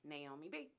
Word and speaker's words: Naomi B: It's called Created to Worship, and my name is spelled N-A-O-M-I, and then a B, Naomi B.